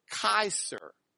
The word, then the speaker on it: Caesar: Remember in Latin, you pronounce the C hard, like a K. So instead of Caesar, you would pronounce it Kaiser.